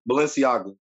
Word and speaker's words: Balenciaga: Hey, you know those Balenciagos? You know those the champ versions that they made? – All wrong. He said that all wrong, but I feel you.